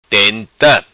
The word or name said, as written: Tenta